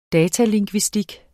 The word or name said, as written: datalingvistik